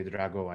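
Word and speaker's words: Dragão: They ended up tying that game with Porto in the Estadio, was Dragao? Was a bit... – Estadio de Drago. I think it means Dragon Stadium in English. – There you go. I always rely on you to get the pronunciations right.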